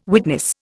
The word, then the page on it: witness: Indian